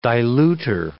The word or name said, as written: dilutor